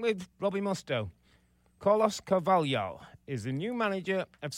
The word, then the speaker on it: Carvalhal: It's Carlos Carvalhal.